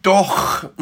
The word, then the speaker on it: doch: Herr, lehre doch mich. The two CHs on doch mich are rather different. The first one on doch is a hard uvular sound.